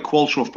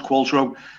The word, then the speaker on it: Qualtrough: It looks like Qualtro, but it's pronounced Qualtro.